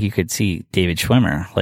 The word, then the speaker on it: David Schwimmer: Prime example, when I was at Chicago studying, David Schwimmer had opened up a theater outside in the suburbs of Illinois because he, did I say his name right from Friends? – Mm-hmm.